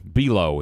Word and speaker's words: Below: Below, as I learned how his name is pronounced, Fred Below. Yes, I've been pronouncing his name wrong for about 30 years now.